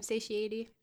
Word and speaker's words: satiety: You had it right the first time, satiety.